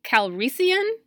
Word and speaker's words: Calrissian: Um, the one thing that I am excited about within this, the solo movie, though, is Donald Glover as uh, Lando Calrissian. Is that how you say it? Yeah. yeah.